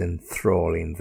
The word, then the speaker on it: enthralling: Now, just in terms of that pronunciation, make sure you get the correct stress in the right place. Enthralling. Enthralling. You got a th, yeah?